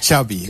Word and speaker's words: Xavi: Xavi. Okay. – Yeah. So I heard the Xavi like chocolate.